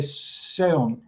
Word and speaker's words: seon: En Corea se dice seon, son, S-E-O-N. Esto equivale a zen, pero es como lo pronuncian allí.